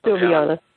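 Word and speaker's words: Silviana: Tarna reports that Egwene spends half her days with Sylvana. It's Sylviana or Sylvana? – Sylviana. – Yeah. Then I've been saying it wrong, too bad.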